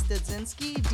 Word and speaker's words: Dudzinski: My first name is Felicia, F-E-L-I-C-I-A, and my last name is Dudzinski, D-U-D-Z-I-N-S-K-I.